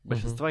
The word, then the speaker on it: большинство: большинство угу. или большинство, не знаю, где правильно поставить ударение. – Большинство. Большинство, да, спасибо.